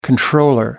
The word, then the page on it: controller: American English